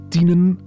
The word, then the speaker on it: Tienen: De wijn, Lisbeth, die komt vandaag uit Tienen, dus je mag de i wat langer uitspreken.